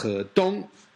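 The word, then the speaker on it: He Dong (何東): In Mandarin, that surname is pronounced He Dong.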